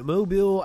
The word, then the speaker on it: Mobile: Richardson was then admitted to a hospital in Mobile, Alabama. And I said it correctly. Till Friday, because you say it the way the locals say it.